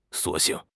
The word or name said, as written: 索性